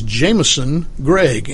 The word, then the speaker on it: Jameson Gregg: Our guest today is Jameson Gregg, and I understand that I mispronounced that in the last segment. Jameson, J-A-M-E-S-O-N, Gregg, G-R-E-G-G.